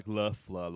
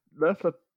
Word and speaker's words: Loeffler: I've been going with Loeffler, like Loeffler, like it's German.